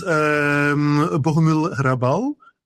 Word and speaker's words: Bohumil Hrabal: Well, for instance, Bohumil Hrabal, I like a lot. Do I pronounce it correctly? Or? – Very good pronunciation.